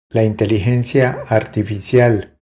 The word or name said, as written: La inteligencia artificial